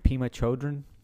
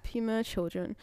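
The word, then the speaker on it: Pema Chödrön: Pema Chodron. – Yeah, Pema Chodron.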